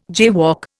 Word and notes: jaywalk: Indian

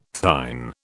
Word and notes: thine: British English

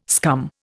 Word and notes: scum: Indian

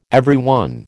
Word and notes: everyone: American English

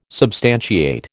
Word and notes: substantiate: American English

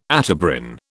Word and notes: Atebrin: British English

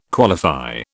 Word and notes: qualify: British English

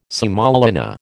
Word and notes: semolina: American English

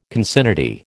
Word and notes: concinnity: American English

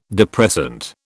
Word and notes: depressant: British English

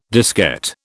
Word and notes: diskette: British English

